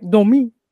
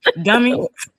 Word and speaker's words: Dami: So it might be like Dami, you know. – Oh, I might just be saying it wrong. – Dummy. – Not Dummy.